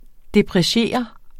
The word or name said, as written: depreciere